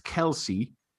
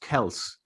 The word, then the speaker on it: Kelce: he gets Kelce, but it's actually pronounced Kelce.